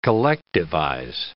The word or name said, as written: collectivize